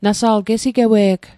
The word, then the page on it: gesigewei: Mi'gmaq